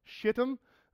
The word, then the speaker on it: Shittim: It's called Shittim. Yes, I'm saying that right.